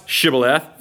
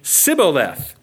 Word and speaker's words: Shibboleth: And if you were an Ephraimite, you couldn't pronounce Shibboleth. Verse 6, you would say Sibboleth.